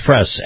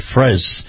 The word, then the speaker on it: Frese: His name is Kyle, and I believe it's pronounced Fress, F-R-E-S-E. So I'm going to pronounce it that way, Fress.